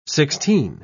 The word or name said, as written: sixteen